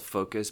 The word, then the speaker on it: Phocus: It's called Focus, but it's spelled P-H-O.